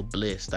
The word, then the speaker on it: Blxst: And I just realized that that's how you say this nigga name. It's spelled BLXST, but this shit stand for Blessed.